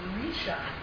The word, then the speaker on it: Risha: There is an Aramaic word that means head, and it's pronounced Risha. Go ahead, try it. Risha.